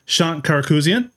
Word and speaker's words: Shant Karakouzian: We have Shant Karakouzian.